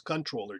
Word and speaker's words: comptroller: And I looked on their website, though, comptroller general of the United States, or of course, it should be pronounced controller general.